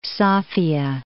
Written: Sofiya

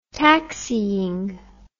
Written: taxiing

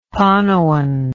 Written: panoan

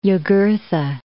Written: Iugurtha

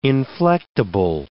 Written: inflectable

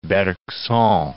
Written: Bergson

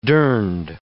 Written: durned

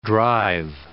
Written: drive

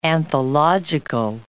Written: anthological

